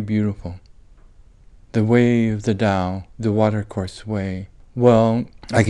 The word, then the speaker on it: Tao: The Chinese word that comes to us that you spell with in the old spelling, romanization, that T-A-O, that you pronounce with a D. Now it's also written with a D-A-O.